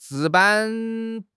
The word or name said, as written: 值班